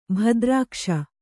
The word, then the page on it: ಭದ್ರಾಕ್ಷ: bhadrākṣa